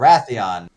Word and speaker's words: Rathian: Yep, Rathian.